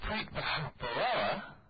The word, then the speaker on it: Preet Bharara: Yesterday, Preet Bharara.